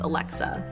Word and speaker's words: Alexia: My name is spelt as Alexia. There's a silent I in there, but it's pronounced Alexa.